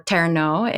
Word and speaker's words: terno: Now, you've heard me talk about the terno before, or terno in with the Filipino pronunciation.